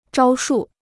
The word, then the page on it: 招数: zhāo shù